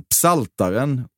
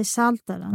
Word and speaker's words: Psaltaren: Du hatar inte att uttala P i bibelboken Psaltaren ostumt. – Du menar att jag ska säga saltaren? – Nej, du gör ett nummer av att säga psaltaren.